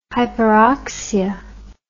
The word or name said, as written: hyperoxia